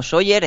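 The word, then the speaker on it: Sóller: Soller, con el acento en la primera O.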